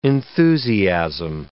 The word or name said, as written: enthusiasm